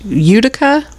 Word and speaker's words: Utica: Hotel, and I'm not sure how this is pronounced. Utica? – Sounds right to me.